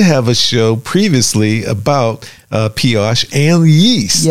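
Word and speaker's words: Pesach: You know, because cleaning products, they're having special offers and sections of kosher for Piash. Did I pronounce that right? – Yes, you're good. – Piash. P-E-S-A-C-H. Y'all pronounce it for me.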